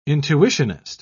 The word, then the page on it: intuitionist: British English